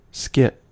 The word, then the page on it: skit: American English